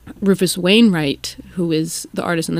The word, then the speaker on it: Rufus Wainwright: Rufus Wainwright.